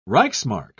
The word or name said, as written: reichsmark